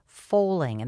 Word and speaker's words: Fowling: The sport is Foling, and that's spelled F-O-W-L-I-N-G.